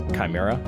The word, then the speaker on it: chimera: Chimera. – It's pronounced chimera, but go on.